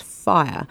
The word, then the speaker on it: whaea: So the the word fire is um, spelled W H A E A, but it's pronounced fire.